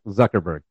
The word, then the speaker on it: Zuckerberg: I can't remember how you pronounce his last name. Fuckerberg, Zuckerberg. That's his name.